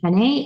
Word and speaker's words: Henne: And then I love a company called, I think it's pronounced Henne, H-E-N-N-E.